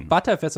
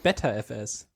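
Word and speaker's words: Btrfs: Genauso wie wie ButterFS ist ja auch ButterFS und nicht BetterFS.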